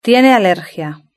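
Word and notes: tiene alergia: Spanish (Spain)

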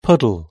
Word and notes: puddle: British English

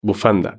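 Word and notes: bufanda: Spain

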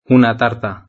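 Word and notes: una tarta: Spanish (Spain)

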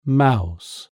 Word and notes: mouse: British English

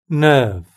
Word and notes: nerve: British English

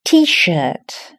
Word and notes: tshirt: British English

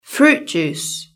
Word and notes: fruit juice: British English

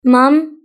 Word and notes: mum: British English

